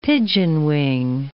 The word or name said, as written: pigeonwing